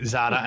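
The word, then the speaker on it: Noblezada: But you also had a great interview with Eva Noble Zeta. I mispronounced that name, Zada, and I shouldn't do that, especially because I've interviewed her before.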